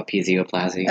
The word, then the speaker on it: episioplasty: Episoplasty, is that how you say it? – No, episioplasty.